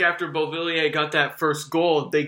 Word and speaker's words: Beauvillier: They got the first goal, you know, the goal by Beauvillier. Is that the right pronunciation? I believe so. – Beauvillier had the first goal, yeah.